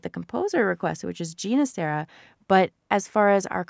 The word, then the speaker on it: Ginastera: So you're saying Ginastera, which is how he wanted it to be pronounced later in life because of his roots. He had, I believe it was the Catalan roots, right? And that's where his name came from.